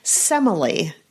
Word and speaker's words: Semele: Semele is the way that the name is pronounced.